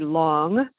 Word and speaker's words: Long: My last name used to be Long, L-O-N-G, which was very easy to pronounce and to spell.